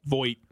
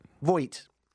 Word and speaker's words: Voight: It will be hosted by the actor John Voight. Am I saying that right? – Voight. – Voight.